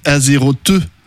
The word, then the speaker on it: Azeroth: On dit Azeroth.